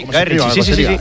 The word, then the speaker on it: Guy Ritchie: Lo he dicho bien, Guy Ritchie. – Sí, Guy Ritchie.